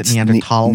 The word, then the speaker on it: Neanderthal: Is that the proper pronunciation of Neanderthal? Neanderthal. – Neanderthal. – Yep.